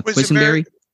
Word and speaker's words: Quessenberry: They had Scott uh, Scott Quis, uh, how do you say the name uh, Quessenberry. – Quessenberry, yeah.